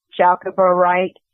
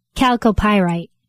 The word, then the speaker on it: chalcopyrite: C-H-A-L-C-O-P-Y-R-I-T-E. I guess that's chalcopyrite, heated or treated to make the rainbow colors. Do you know that stone? – Chalcopyrite. – Oh, chalcopyrite. Thank you. Thank you. – Chalcopyrite? – Yeah, chalcopyrite.